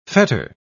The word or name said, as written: fetter